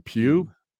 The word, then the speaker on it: Pugh: We had Florence, and Justin and I have been struggling with how you pronounce her last name. Pugh.